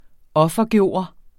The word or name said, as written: offergøre